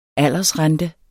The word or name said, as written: aldersrente